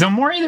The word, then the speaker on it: Omori: So in Game Pass right now, we got Omori. Is Omori the right way to say that? – Omori, yeah.